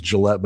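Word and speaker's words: Gillet: My last name is Gillette, but it's spelled wrong. I don't know why my ancestors didn't put an E on the end. So it looks like Gillette or Gillette. So my website is like the razor, because that's what I'm used to telling people.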